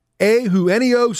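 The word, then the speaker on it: Eugenio: Ehuenio, I said it right the first hour, and I screwed it up the second hour. Ehuenio Suarez, there we go.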